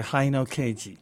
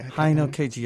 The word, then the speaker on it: Haino Keiji: We're going to play some, I've always mispronounced this, Keiji Haino. – That's okay, Haino Keiji. Haino Keiji, yeah, he's one of the most interesting noise musicians in Japan today, but based in Tokyo.